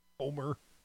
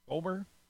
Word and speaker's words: Comer: Comer, like Comer, like rhymes with Gomer, maybe? – Like Homer, Kentucky. But Comer.